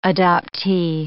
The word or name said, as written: adoptee